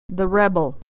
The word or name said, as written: the rebel